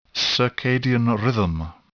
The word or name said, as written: circadian rhythm